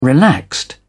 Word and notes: relaxed: British English

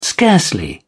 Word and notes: scarcely: British English

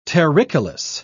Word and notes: terricolous: British English

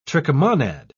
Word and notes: trichomonad: British English

